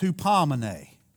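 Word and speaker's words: hupomene (ὑπομονή): It's hupomene in the Greek. Hupomene. It's short E on the end.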